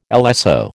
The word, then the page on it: LSO: American English